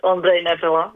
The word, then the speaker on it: Ondrej Nepela: Yes, so, and a very good pronunciation. So it is Ondrej Nepela, well done.